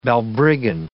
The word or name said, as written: balbriggan